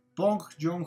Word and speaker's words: Bong Joon: Lo vamos a decir como pues en los Oscars lo mencionaron, que es Pong jong ho Sí, exactamente. Se escribe Bong Joon-ho, pero parece que se pronuncia con P.